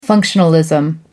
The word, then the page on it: functionalism: British English